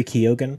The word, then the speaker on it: Keoghan: how do you say his last name? Is it Barry Keoghan? – Keoghan? – Barry Keoghan, I think is how you say it.